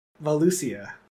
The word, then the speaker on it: Valusia: All right, so Eyes of Vasula. – Nope. – That's how you say it. – No, gotta look at all the letters. – Valusia.